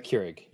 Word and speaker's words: Keurig: I always mispronounce the name. Keurig. Am I pronouncing it right? – Keurig. Yep.